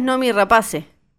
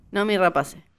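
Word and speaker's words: Noomi Rapace: Ella es Nomi Rapace. – ¿Se dice así? – Decimos así, así está como... – Ah, ok. – Nomi Rapace. – Exacto.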